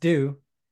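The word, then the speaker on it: deux: Just got me thinking, like, we're doing the Scooby panel, Scooby-Doo movies, and in French, 2 is pronounced do, so the French poster for Scooby-Doo 2 is Scooby-Doo-Doo.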